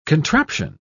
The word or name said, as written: contraption